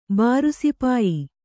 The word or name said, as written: ಬಾರುಸಿಪಾಯಿ